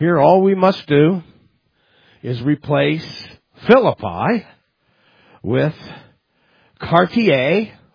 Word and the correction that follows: Chartiers: Oh, that's Chartiers. I was pronouncing it in the French.